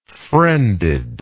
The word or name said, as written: friended